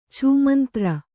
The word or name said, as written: ಛೂಮಂತ್ರ